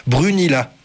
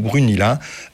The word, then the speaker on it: Brunila: Temu Brunila. B-R-U-N-I-L-A. Je suis désolé pour la prononciation. Temu Brunila à la télé.